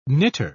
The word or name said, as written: knitter